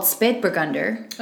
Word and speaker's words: Spätburgunder: Because in Germany, it's called Spätburgunder. – Okay. – And... – Oh my God, good pronunciation.